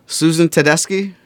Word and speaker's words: Susan Tedeschi: And whichever one of you did, you can help me with the pronunciation. Susan Tedeschi? – Yeah. You've got the pronunciation right, and she is on my list.